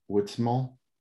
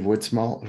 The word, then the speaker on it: Woodsmall: This is the host of The World According to Mark, and we are broadcasting an interview with Steve Woodsmall. Am I pronouncing that correctly, Steve? – Yes, sir. – Steve Woodsmall, who is running for Congress, and this program will be available on Facebook, The World According to Mark.